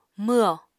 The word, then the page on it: mwho: Apatani